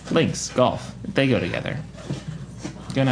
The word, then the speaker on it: Links: Lynx? Banagher. Banagher? – That's a little bit closer. – Last name is Lynx. – It is Lynx. – L-I-N-K-S. Yeah.